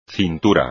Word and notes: cintura: espagnol

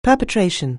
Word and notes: perpetration: anglais

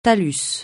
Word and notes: talus: francais

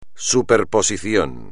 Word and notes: superposición: espagnol

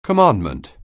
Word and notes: commandment: anglais

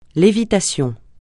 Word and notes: lévitation: francais

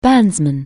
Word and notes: bandsman: anglais

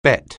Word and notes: bet: anglais

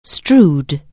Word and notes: strew: anglais

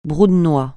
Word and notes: brou de noix: francais

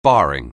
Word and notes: barring: anglais

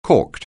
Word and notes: corked: anglais

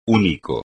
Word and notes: único: espagnol

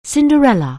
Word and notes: Cinderella: anglais

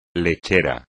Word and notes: lechera: espagnol

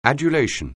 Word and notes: adulation: anglais

